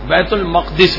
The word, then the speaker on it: بیت المقدس: دوسری ایک اور چٹان بھی ایسے ہی ہے معلق, وہ چٹان بیت المقدس میں ہے, یہ صحیح تلفظ بیت المقدس ہی ہے.